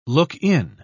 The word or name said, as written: look-in